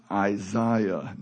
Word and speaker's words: Isaiah: That's pronounced Isaiah, not Isaiah.